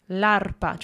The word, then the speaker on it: l'arpa: L'arpa. Try and pronounce the R. – L'arpa.